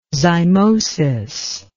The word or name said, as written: zymosis